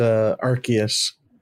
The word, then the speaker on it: Arceus: In my heart, it will always be Arceus. – Oh, did they change it? Apparently the official pronunciation is Arceus. I always thought it was Arceus.